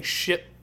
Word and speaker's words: Shipt: S-H-I-P-T. – Yeah. – It's like Uber for groceries. – Yeah. – But the P is silent. Right? – Nope. It's like shipped. – Oh, okay. – Like the word shipped, but with just a T.